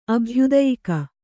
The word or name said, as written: ಅಭ್ಯುದಯಿಕ